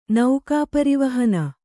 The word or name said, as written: ನೌಕಾಪರಿವಹನ